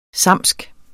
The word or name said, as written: samsisk